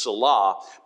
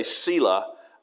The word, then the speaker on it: Selah: The correct pronunciation is Selah, but some people say Selah, and they leave that H off and just make the A long.